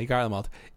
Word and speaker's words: Garlemald: Ilsebarad is now, which is the continent where the Garlia, Garlemald. – Garlemald, that's how you pronounce it, right? – Yeah, Garlemald.